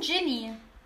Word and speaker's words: Ginny: Zu Ginny's, Genie. wie spricht man? Oh. Ginny. Genau.